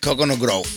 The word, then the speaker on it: Coconut Grove: Coconut Grove.